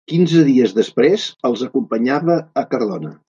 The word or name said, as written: acompanyava